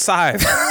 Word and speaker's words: Scythe: My number five rhymes with five, and it's Scythe.